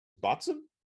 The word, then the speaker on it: Boatswain: That's not actually how you pronounce it. It tells you how to pronounce it on the can, thankfully. It says, I think that's a Botson.